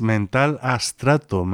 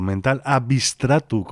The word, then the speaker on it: Mental Abstrato: Otro grupo que pudimos ver por allí es Mental Astrato, Mental Abstrato, como suelen pronunciar por allí.